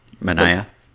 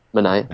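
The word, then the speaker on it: Manaea: I have no idea how to pronounce it. – Manea. – Manea, okay.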